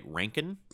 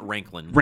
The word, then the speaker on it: Rankin: Rankin, not Ranklin. – Rankin, R-A-N-K-I-N.